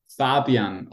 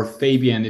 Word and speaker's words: Fabian: Fabian, or Fabian if you want to pronounce it in English.